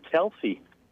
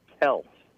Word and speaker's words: Kelce: It's not Kelce. The last name is actually supposed to be pronounced Kels.